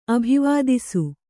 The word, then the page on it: ಅಭಿವಾದಿಸು: Kannada